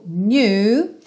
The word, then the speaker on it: knew: O passado do verbo to know, knew, e o K é mudo, assim como no presente, know, ok?